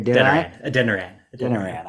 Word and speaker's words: Adeniran: I want to pronounce that correctly. – Adeniran. – I screwed it up.